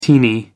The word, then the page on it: teeny: American English